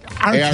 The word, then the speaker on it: Arcada: A-C-A. – A-R. – Arcada? – A-R-C-A-D-A. – Yeah, like Arcade. Arcada.